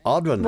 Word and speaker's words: Odhran: Jennifer, 100%. Audrin. – What? – Yeah, that's exactly how you say it.